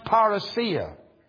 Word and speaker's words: parousia: Well, the word's pronounced parousia. Parousia.